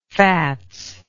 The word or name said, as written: fats